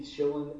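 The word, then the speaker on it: Shillan: On Twitter, it's at Keith Schillan MMA.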